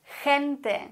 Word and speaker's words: gente: Was ist, wenn das G direkt vor E oder vor I steht? Dann müssen wir es wie ein H oder wie ein Gente, wie ein CH eigentlich aussprechen. La gente. Okay? Gente. Oder GI wäre zum Beispiel Gimnasio. Da schwingt ein bisschen ein CH mit.